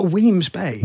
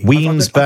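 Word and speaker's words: Wemyss Bay: So currently, without giving, how do you pronounce it? Wemyss. – Oh, Weems Bay. – Weems Bay. – I've been instructed on this. It's definitely Weems Bay.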